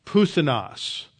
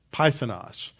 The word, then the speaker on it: Puthenos: Now, sometimes you'll read that written P-Y because when you translate the upsilon in Greek, it is often transliterated as a Y, so it's spelled Puthenos, Pythonos. So, but it's Puthenos. It's an upsilon.